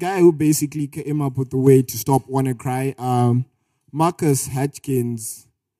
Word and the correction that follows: Marcus Hutchins: Is that how you pronounce it? – Yeah, it's Marcus Hutchins, yeah.